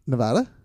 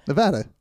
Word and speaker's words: Nevada: That Reno is in, how do you say that? – Nevada. – Good. If you say Nevada, we were gonna have. – What did I say? – Hands. You said Nevada. – Nevada.